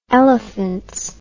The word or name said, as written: elephants